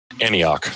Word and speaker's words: Antioch: Anticock. How do you say that? – Antioch. – Antioch, yes.